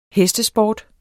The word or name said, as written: hestesport